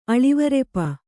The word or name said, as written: ಅಳಿವರೆಪ